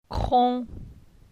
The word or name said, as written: Cron